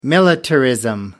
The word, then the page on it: militarism: American English